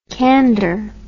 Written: kander

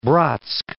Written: Bratsk

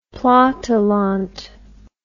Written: platteland

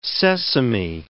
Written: sesame

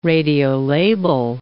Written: radiolabel

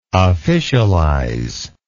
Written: officialize

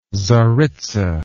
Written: tzaritza

